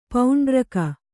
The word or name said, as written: ಪೌಂಡರೀಕ